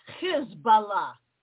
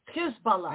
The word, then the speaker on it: Hezbollah: By the way, pronounce it Hezbollah, because Hezb in Arabic means party, Allah means God. Party of God. If you don't pronounce it that way, you don't get the meaning.